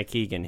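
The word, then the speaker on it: Keoghan: Do you know how to pronounce Keegan. – His name? Is it just Keegan? – Yeah. – Okay, Barry Keegan, who you might recognize as the little kid on the boat in Dunkirk.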